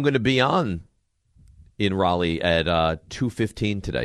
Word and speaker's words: Raleigh: Can you guys say it correctly? – In Raleigh. – You guys are doing that to the Canes fans down here? That's what you're doing? – It's Raleigh.